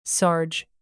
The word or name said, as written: sarge